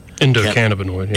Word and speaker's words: endocannabinoid: And there's no marijuana in these products, but they're very effective because we have the cannabinoid, did I pronounce that right? – Endocannabinoid. – Yeah. Endocannabinoid in our system already.